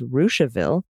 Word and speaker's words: Russiaville: Russiaville is Rushaville.